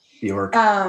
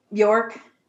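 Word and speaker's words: Björk: Because he does the Bjork, I always say her name wrong. – Bjork. – Bjork.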